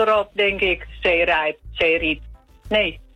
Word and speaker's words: Zeerijp: Met de klemtoon op Rijp. Want als je het in Groningen zegt, dan zeg je Zeerijp. – Zeerijp. – En dan is de klemtoon ook op het Riep in plaats van het Rijp.